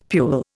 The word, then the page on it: pule: Indian